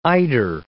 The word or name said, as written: eider